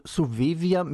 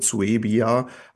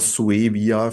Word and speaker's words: Suevia: Und Suevia mit W gesprochen dann? – Ich denke, man kann es auch mit B sprechen, also wie manche andere Aussprachen mit Suevia, aber Suevia, für das haben wir uns entschieden, klassisch lateinisch. Und deswegen, wie du sagst, italienisch ist natürlich dem Lateinischen maximal nahe.